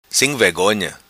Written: Sim Vegonha

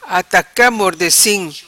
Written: Ataca Mordecim

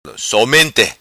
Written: Somenta

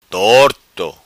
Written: Torto

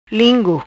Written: Lingu

